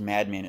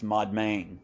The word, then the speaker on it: Madmen: It's not pronounced madman. It's Modmain, and it was just name of a place there. But they didn't pronounce it madman. They pronounced it Modmain.